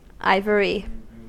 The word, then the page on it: ivory: American English